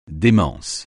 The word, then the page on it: démence: français